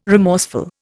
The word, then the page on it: remorseful: Indian